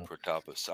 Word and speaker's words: Protopus: Yeah, that it is emphasis on emphasis on the second syllable. So. – Protopus.